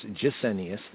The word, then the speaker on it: Gesenius: Now Strong's for the Old Testament based a lot on Gesenius, or some pronounce his name as Gesenius, the GJ kind of vibes in English, Gesenius.